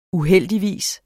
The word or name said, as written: uheldigvis